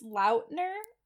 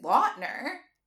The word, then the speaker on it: Lautner: Oh, also, did you know it's Lautner? – Oh. I've always pronounced it Lautner.